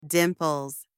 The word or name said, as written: dimples